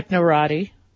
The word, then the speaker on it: Technorati: Technorati. – Is that what it's pronounced? Technorati? – Yeah, uh-huh. – Technorata, uh-huh. – Did I say Technorata? – Mm-hmm.